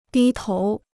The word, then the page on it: 低头: dī tóu